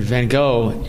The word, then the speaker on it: Van Gogh: And for those who don't know, and probably some of you do, that Van Gogh is pronounced originally in Holland, Van Gogh. Both of the G's are guttural in G-O-G-H. So it's some people think just one is, but it's Van Gogh.